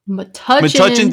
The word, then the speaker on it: Metuchen: Metuchen these nuts!